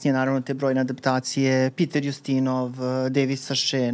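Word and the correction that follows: Suchet: naravno, Saše izgovara. – Suše. Suše. Po francuski suše.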